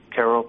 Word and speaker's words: Q'ero: Yes, Sebastian is a member of the Quero people, Q'ERO, pronounced Quero in their language.